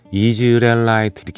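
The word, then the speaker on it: Israelites: 어, 이스라엘라이트, 이즈라엘라이트 이렇게 발음하네요.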